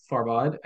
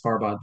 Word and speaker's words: Farbod: So my actual name is Farbod, and my artist name is Farbod.